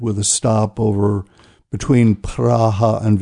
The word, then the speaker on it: Praha: My wife and I will be celebrating our 50th wedding anniversary in Prague, or more accurately pronounced Praha, in the Czech Republic.